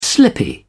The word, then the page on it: slippy: British English